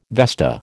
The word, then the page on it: vesta: American English